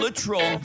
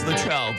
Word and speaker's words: Littrell: The guest is Brian Littrell from the Backstreet Boys' son. I always thought it was Littrell, but I'm told it's Littrell, and to never say it like that again, so I won't.